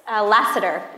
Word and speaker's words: Lassiter: Lassiter.